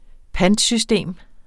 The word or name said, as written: pantsystem